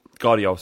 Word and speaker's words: Gvardiol: I don't, like, there's Indica, there is Bastoni, there's Pau Torres, there's Bremer, there is, I can't pronounce the other one's name, Gvardiol? – Yeah. – Gvardiol, sorry.